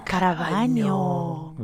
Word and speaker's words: Caravagno: How do you say your last name? – Caravagno. Which means face bathroom.